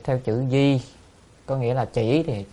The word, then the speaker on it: Di: Còn chữ Di này có một cách đọc khác là đọc là Dị. Nếu đọc là Dị thì là Dạ, thì Dạ Thưa Thế Tôn đó. Đại Từ Thính Sát, thì hai cách đọc này đều được, Di hay Dị thì được, nhưng mà thông thường người ta đọc là Di.